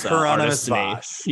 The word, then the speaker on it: Hieronymus Bosch: Hieronymus Bosch. – Yeah, okay. – Hieronymus Bosch.